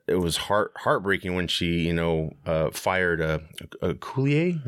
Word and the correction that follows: Okoye: Is that how you say her name? The main. – Okoye.